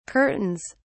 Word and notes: curtains: British English

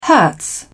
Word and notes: hertz: British English

